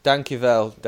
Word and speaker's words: Dankjewel: Dankjewel, Dennis. Dankjewel. – Oh, look at that pronunciation.